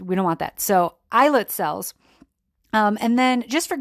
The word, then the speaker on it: islet: One, I need to humbly correct myself because I was pronouncing I-S-L-E-T, I was pronouncing that word like a idiot, and I was pronouncing the S. But guess what? I looked it up, and it's totally not pronounced with the S. So I'm sure half of you are like, yeah, P-A-K, I definitely knew that. Yeah, well, it left my brain. So we're going to pronounce that word islet. So again, islet. All those times I pronounced it wrong.